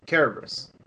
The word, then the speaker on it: Cerberus: Whenever you're reading Cerberus, which is, it's spelled with a Latin, it's spelled the Latin way, but it's pronounced Cerberus.